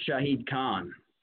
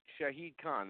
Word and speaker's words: Shahid Khan: I don't want to mispronounce it, but I think it's Shahid Khan. – Yeah, that's it. Shahid Khan.